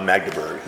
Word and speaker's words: Magdeburg: a couple nights ago, we were in Mandelberg. Is that how you say it? Uh, Magdeburg. – Magdeburg.